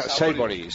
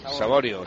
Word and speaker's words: Saborío: Supongo que se pronunciará de otra manera, pero escribirse se escribe Saborío. – No, no, se pronuncia Saibori. Saibori. – Saborío será, ¿no? – Saborío. – Saborío. Saborío. Bueno, pues Saborío queda mejor.